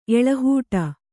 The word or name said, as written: ಎಳಹೂಟಿ